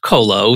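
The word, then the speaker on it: QOLO: So it's Q-O-L-O and pronounced Kolo.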